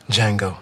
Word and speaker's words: Django: Django. – The D is silent.